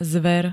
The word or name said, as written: zver